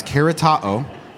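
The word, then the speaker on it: keratao (χαριτόω): Mark told me how to say this because it looks like cheritu, but it's keratao.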